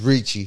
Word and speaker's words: Ricci: Is it Ricci? Is it Ricky? – Yeah, it's Ricci.